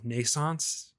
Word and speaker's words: Naissance: Like parts of this game remind me a lot of Naissance, I think is how you would pronounce it. N-A-I-S-S-A-N-C-E-E.